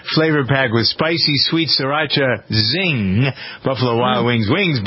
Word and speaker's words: Sriracha: Buffalo Wild Wings Sauce Lab, introducing Sriracha Sizzle. Is that how you pronounce it? S-R-I? – Sriracha.